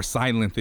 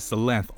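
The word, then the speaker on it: Sylenth: Or Silenth, if you want to pronounce it. I pronounce it as Silenth, only because how do you pronounce synth? Synth, right? Whatever.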